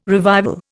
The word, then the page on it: revival: Indian